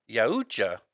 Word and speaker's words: Yautja: And it's spelled, you know, Y-A-U-T-J-A. – I'm going to say it's Yautja. – Yautja. Pretty close, Yautja. Though. – Oh, okay. Yautja. Yep. – So these, you know, the predators are actually called Yautjas.